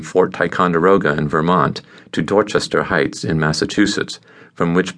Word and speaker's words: Dorchester: We lived in the section of Boston called Dorchester, pronounced Dorchester, on Glen Rose Road.